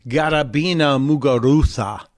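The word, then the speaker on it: Garbiñe Muguruza: And the fact that in the course of getting to the finals today against Garabina Muguruza, which is how you pronounce the Spaniard's name, she has the Castilian pronunciation, Garabina Muguruza.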